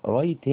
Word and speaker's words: write: write هنا ال W الأولى ديت silent لا تنطق، خلاص كده.